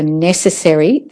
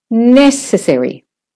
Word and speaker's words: necessary: And for necessary, the first syllable is the stressed one. Necessary. Necessary. You can hear the rest is said quickly. Competition. It's not competition. Competition. The com, C-O-M, is a secondary stress syllable.